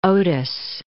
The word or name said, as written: Otis